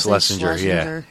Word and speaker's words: Schlesinger: Oh, I went and saw Eliza Schlesinger. – What? – Is that how you say it? – Schlesinger, yeah.